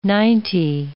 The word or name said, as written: ninety